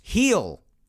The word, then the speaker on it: Gil: Luis Gil. And I've actually pronounced his name wrong in the past, I'll admit. I'm usually really good with names, but I pronounced the G in Gil because it's spelt G-I-L.